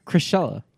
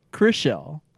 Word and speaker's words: Chrishell: Chrishella. – Chrishell. – Chrishell. – Like Chris with a shell.